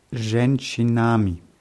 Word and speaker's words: женщинами: Und Женщинами. – Richtig, nur die Betonung ist auf dem Je.